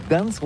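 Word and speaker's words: Gdańsk: Ça s'écrit G-D-A-N-S-K. On dit Gdansk ou Gdansk, on prononce le G ou pas?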